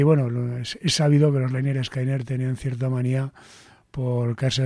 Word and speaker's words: Lynyrd Skynyrd: El disco se pronuncia Lynyrd Skynyrd, era el nombre del grupo que lo habían puesto por un profesor que tuvieron, algo así, es decir, el primero.